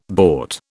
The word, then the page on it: bought: British English